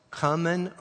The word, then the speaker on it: cumin: It's spelled C-U-M-I-N. And it's either pronounced cumin or cumin. I've heard it both ways.